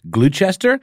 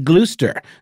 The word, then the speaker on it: Gloucester: Gloucester, or is it Gloucester? G-L-O-U-C-E-S-T-R.